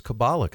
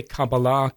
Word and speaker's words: Kabbalah: So to cut to the chase, as far as I say Kabbalah is a fine, Kabbalah. – It's a fine pronunciation, what you're saying. I'm doing the straight Hebrew pronunciation.